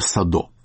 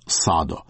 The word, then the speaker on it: Садо: Интернет дает ударение Садо. Я специально перед нашей программой созвонился с людьми, которые лично знали этого человека. И они настаивают ударение на «А». Михаил Садо.